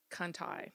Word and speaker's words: Conti: But I think they call it Conti. Mm. Is actually how they pronounce it, Conti, although I would say Conti.